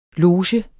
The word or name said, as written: loge